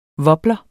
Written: wobler

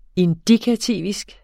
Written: indikativisk

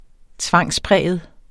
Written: tvangspræget

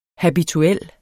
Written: habituel

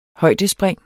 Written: højdespring

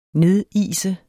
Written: nedise

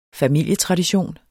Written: familietradition

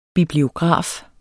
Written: bibliograf

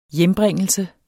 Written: hjembringelse

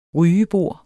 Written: rygebord

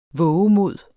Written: VOVEMODS

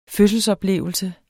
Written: fødselsoplevelse

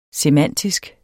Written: semantisk

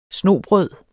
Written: snobrød

